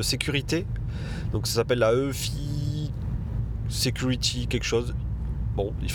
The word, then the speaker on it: eufy: Donc c'est la marque EFI, donc ça s'écrit E-U-F-Y qui faisait, je crois, jusqu'à présent euh, principalement des aspirateurs robots et des petites caméras euh, de surveillance du domicile.